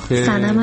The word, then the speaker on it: سنم: سنم هستم از میلان. – خیلی متشکرم سمن جان. سنم جان، سمن چیه؟ سنم، درست تلفظ کن اسم مخاطب رو، خجالت هم نمیکشه به خدا.